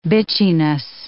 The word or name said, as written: bitchiness